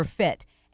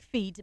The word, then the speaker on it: Fit: Let me just say that it's Alexandria River Fit. And it's pronounced, it's spelled. – It looks like feet. – It looks like feet, but it's Fit.